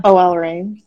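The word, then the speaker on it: OL Reign: OL Rain.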